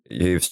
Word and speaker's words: Yves: Right now, I'd say Yves Tumor. It's spelled Y-V-E-S space Tumor.